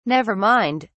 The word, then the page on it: nevermind: British English